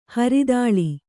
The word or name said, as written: ಹರಿತಾಳ